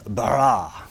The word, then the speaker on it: bara: Now, the Hebrew word for create is the word bara. You're meant to roll your R's, but I can't do that. So bara, something, roll the R.